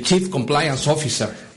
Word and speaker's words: Chief Compliance Officer: Qué bueno que, digo, estamos escuchando de ti que eres el, Chief Compliance Officer. ¿Lo dije bien? Sí, sí.